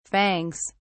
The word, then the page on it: fangs: British English